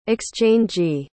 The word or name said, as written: exchangee